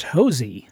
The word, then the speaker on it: Hosea: Well, it's spelled Hosea, H-O-S-E-A, but my wife, who is from Texas, will tell you that down there, it's pronounced Hosey.